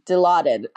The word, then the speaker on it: Dilaudid: And I was on diluted. I hope I'm saying that right. Dilaudid. I said it wrong, damn it.